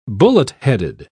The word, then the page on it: bullet-headed: British English